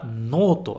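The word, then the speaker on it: noto (ノート): E não se esquece de pronunciar esse noto, de prolongar. Noto, ok? Noto. É diferente de noto. Noto, noto. Tudo bem? Em japonês isso faz diferença.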